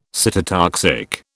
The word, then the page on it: cytotoxic: British English